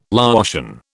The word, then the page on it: Laotian: British English